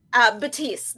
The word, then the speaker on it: Baptiste: Baptiste. The P Baptiste. Is silent.